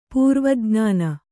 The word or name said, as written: ಪೂರ್ವಜ್ಞಾನ